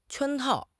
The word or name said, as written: 圈套